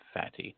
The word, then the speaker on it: Fatty: Yes, that is correct. I said Fati. His last name is spelled F-A-T-T-Y.